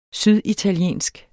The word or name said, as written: syditaliensk